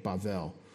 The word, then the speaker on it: Bavel: B-A-B-A for this case, L. Babel. Oh, no, that's a segel. Babel. Bavel, actually, is how that would be pronounced in this writing, Bavel.